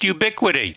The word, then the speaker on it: Ubiquiti: Ubiquiti or whatever. – It's pronounced Ubiquiti.